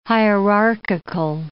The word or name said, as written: hierarchical